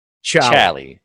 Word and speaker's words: Charlie: And we are saying it correctly. It doesn't have an R. We should say that. The mascot, the skull with the bat wings, his name is Charlie.